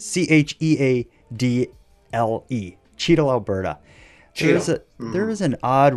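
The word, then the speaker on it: Cheadle: The Cheeto sculpture and the Hope BC celebration both include weird monuments.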